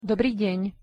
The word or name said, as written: dobry den